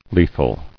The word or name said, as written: Lethal